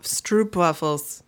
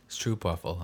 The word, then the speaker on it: Stroopwafel: Wait, how do you say it? – Stroopwafel, I think. – Stroopwafel. – Stroop. – Stroop. – You got like an umlaut over the O's, like the two dots.